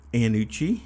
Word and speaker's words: Iannucci: But it's directed by Armando Iannucci.